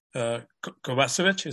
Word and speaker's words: Kovacevic: Kovacevic.